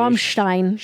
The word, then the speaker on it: Rammstein: You know, you stress to me the proper German pronunciation. – Rammstein. – Stein. – Rammstein. – Yeah, if I say W's instead of V's.